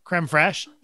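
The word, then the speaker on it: crème fraîche: Creme fraiche. – Creme fraiche? – Creme fraiche, yeah.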